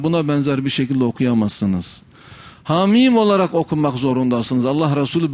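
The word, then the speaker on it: Hamim (حم): Kesik kesik okumak zorundasınız. Hamim olarak okumak zorundasınız. Hame gibi bir şekilde okuyamazsınız. Hume gibi bir şekilde okuyamazsınız.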